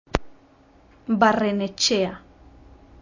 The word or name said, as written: barrenetxea